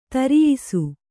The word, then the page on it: ಧರಿಯಿಸು: Kannada